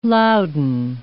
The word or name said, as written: louden